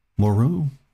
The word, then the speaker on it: Moreau: Moreau, I think is how you pronounce it. M-O-R-E-A-U is how you spell it.